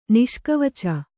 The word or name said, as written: ನಿಷ್ಕವಚ